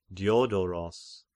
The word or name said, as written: Diodoros